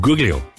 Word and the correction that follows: Guglielmo: It's not Guglio, he's Guglielmo.